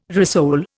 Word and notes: rissole: Indian

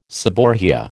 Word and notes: seborrhoea: American English

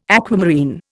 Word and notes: aquamarine: Indian

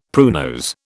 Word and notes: pruinose: British English

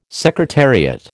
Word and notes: secretariat: American English

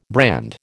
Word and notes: brand: American English

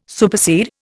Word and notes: supersede: Indian

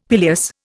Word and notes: pileus: Indian

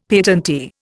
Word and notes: patentee: Indian

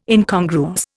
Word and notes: Incongruous: Indian

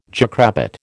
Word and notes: jackrabbit: American English

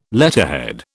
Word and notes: letterhead: British English

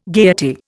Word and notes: gayety: Indian

